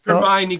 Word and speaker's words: Hermione: Hermione Granger.